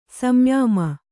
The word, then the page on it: ಸಂಯಮ: samyama